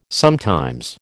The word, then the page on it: sometimes: American English